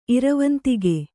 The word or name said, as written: ಇರವಂತಿಗೆ